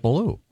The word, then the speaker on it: Ballou: But Boogaloo has nothing to do with Ballou. – No. – It's Cat Ballou. – No, that doesn't have anything to do with Boogaloo. – It rhymes with Boogaloo.